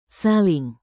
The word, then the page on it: saling: indonesian